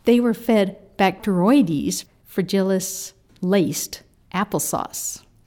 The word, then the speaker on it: Bacteroides fragilis: It is Bacteroides fragilis.